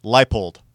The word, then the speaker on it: Leipold: Leopold. – Lance, say it right, Leipold.